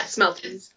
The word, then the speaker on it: Smeltings: And again, and the next thing that's fucking wrong with this, is it pronounced Smeltings? Did I fuck that up? The private school that Dudley's going to go to? – Yeah, Smeltings.